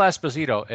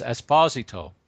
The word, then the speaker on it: Esposito: Phil Esposito, it's Esposito.